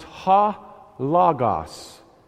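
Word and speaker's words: ha logos (ὁ λόγος): But the Greek word underneath it is pronounced ha logos, and it's Greek for the Word.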